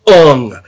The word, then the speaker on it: Aang: Aang is Ung.